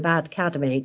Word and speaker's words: ADcademy: Oh, well done for the pronunciation of ADcademy, because that's a tricky one. Yeah, so the Brixton Finishing School and ADcademy, which is the word AD, which is short for advertising next academy, are